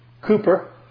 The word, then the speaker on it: Cowper: We sang one, William Cooper. It's spelled Cowper, but it's Cooper.